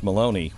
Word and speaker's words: Maloney: The way, that question came from Miss Maloney, which rhymes with baloney.